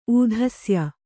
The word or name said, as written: ಊಧಸ್ಯ